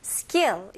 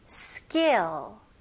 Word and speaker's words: skill: 이거는 이름절로 skill. skill, 네, 연결하셔야 됩니다.